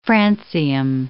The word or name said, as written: francium